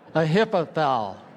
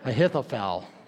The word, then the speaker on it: Ahithophel: Try to say that name with me, all right? Ahithophel. Ahithophel. I said it wrong now. Ahithophel. There you go.